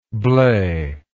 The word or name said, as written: Blais